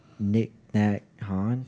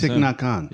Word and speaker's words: Thich Nhat Hanh: Nick Nat Han? – Yeah. That's Thich Nhat Hanh. – Yeah. – Thich Nhat Hanh.